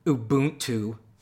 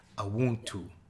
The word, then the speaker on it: Ubuntu: And so I first said Ubuntu. that's the white guy from Minnesota. So the first lesson is that the B is not pronounced the way we pronounce B. It's like a Ubuntu.